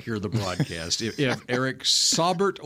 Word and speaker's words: Saubert: Would you like the correct pronunciation of Eric, S-A-U-B-E-R-T? – You know it now? – According to the Denver Broncos pronunciation guide for him, Saubert. – Saubert. – Yeah, that sounds smart. – Eric Saubert. Saubert. – I bet it's Saubert, but they've got the emphasis on the Bert.